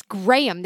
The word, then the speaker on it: Graham: I know, all night, you and Cody going, Graham, Graham, and it's Graham. There's 2 syllables.